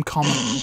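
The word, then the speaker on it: Kama: Meanwhile, Tina meets Yusuf Karma. Oh, I thought it was Comma. They call him Comma. But it's spelled...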